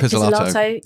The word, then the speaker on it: Pizzolatto: Pizzolatto.